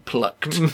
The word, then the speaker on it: plucked: How old were you when you were first pullocked? – Here I'm coming at you. I was pullocking you. Pullocking you. I'm going to pullock you. – No, that was me mispronouncing plucked.